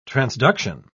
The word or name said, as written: transduction